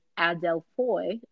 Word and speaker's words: adelphoi: And it says that this Greek word for brothers and sisters, adelphoi, I think I said that right. It's A-D-E-L-P-H-O-I, adelphoi.